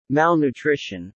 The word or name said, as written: malnutrition